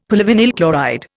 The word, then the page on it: polyvinyl chloride: Indian